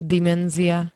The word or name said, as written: dimenzia